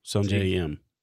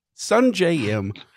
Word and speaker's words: Sungjae Im: No, Sungjae Im. – Min Jung Im. – Sure. See? You see? You can't even say his name. Sungjae Im.